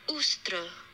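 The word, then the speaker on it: Ēostre: This is how you're going to say it. – Istra or Ustra, Anglo-Saxon.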